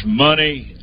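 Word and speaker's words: Munny: So we named this horse Money Rocket. It's a filly, and it will be pronounced Money, M-O-N-E-Y, but it's M-U-N-N-Y, Money Rocket.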